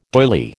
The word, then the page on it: doily: American English